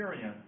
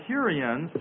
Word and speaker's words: Hurrians: called the Hurrians that occupied the city.